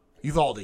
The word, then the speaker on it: Uvalde: I'm saying that wrong. – Is it Uvalde? – Uvalde, and I'm honestly asking because I keep fucking it up. – Yeah, Uvalde, the